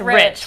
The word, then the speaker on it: Rich: He doesn't spell it like the common Rich, but yes. – But it's pronounced Rich, which means he will teach you how to make money.